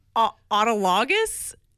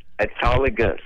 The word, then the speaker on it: autologous: I just want to call and let you know how to pronounce that word. – Please do. – It's autologous. – Autologous. – Autologous. – Yes, sir.